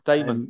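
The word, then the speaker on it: Dahmen: Damon, I don't even know how you pronounce his surname. – Damon. Yeah, it is. – Joel Damon, is it? – Yep.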